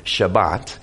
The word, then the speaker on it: Shabbat: So the Sabbath day, or to the Jews in my best pronunciation, Shabbat, is a day of rest.